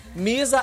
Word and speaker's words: Miza: Misa Alonso é o meu nome nas redes sociais. Do jeito que fala, se escreve. M-I-Z-A Alonso com Z.